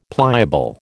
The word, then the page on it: pliable: American English